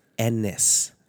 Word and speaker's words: Ennis: The Garth Ennis. – This is now the second year where you it's Ennis. – Ennis. – Yeah, it rhymes with penis.